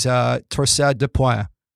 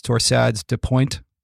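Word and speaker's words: torsades de pointes: One last rhythm related to bradycardia that we should really be aware of is torsade de pointe. That's how we say it in Canada. As they say in the U.S., torsades de pointe, I think it is.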